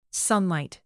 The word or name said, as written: sunlight